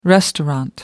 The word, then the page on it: restaurant: American English